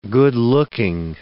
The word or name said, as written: good-looking